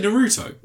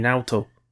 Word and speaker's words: Naoto: Did you say Naruto? – No, Naoto. – How do you spell that? – N-A-O-T-O.